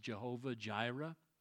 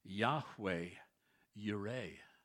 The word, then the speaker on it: Yahweh Yireh: Perhaps you've heard that phrase pronounced Jehovah Jireh. There's a bit of a problem with that. There's no J or V in the Hebrew alphabet, so they didn't pronounce it Jehovah Jireh. It is Yahweh Yireh.